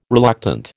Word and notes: reluctant: American English

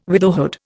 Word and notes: widowhood: Indian

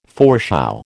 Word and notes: foreshow: American English

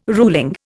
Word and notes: ruling: Indian

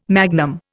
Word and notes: magnum: Indian

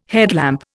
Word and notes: headlamp: Indian

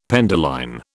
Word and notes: penduline: British English

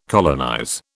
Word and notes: colonize: British English

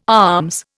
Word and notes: alms: Indian

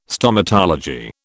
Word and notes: Stomatology: British English

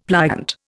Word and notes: pliant: Indian

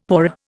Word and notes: paua: Indian

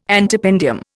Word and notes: antependium: Indian

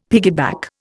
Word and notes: piggyback: Indian

